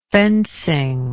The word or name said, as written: fencing